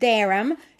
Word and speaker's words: Durham: Αυτός ο John Durham, γιατί το χου δεν προφέρεται, είναι χαμηλό λοιπόν,